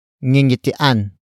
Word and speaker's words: ngingitian: Word for word it's ngingitian, tayo, ng, kapalaran.